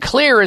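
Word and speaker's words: Xlear: And Clear is spelled with an X, X-L-E-A-R.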